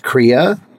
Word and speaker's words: Krea: which, you know, you had the founder of, I think it's pronounced Kriya on recently. – Yeah. – And so this is what are called these like episode 1850. Kriya was spelled K-R-E-A, real-time image manipulation.